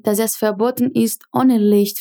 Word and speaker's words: Licht: Prestando atenção à pronúncia de Licht, que tem esse som chiadinho. Ohne Licht Fahrrad zu fahren.